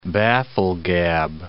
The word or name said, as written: bafflegab